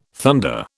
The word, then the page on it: thunder: British English